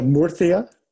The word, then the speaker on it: Murcia: Okay, so Murcia, Almeria, and Granada Okay, forgive my Spanish, pronunciation. You can correct me. – So Murcia. – Thank you. – Almeria. – Yeah. – And Granada.